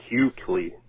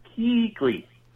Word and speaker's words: Keekley: He was pronouncing it as though it was spelled Q-U-E-K-L-E-Y or something like Q-Kly, like barbecue Kly. – Yeah, like what do you mean? – Like K-E E-K-L-E-Y. Like Keekly.